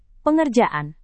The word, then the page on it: pengerjaan: Indonesian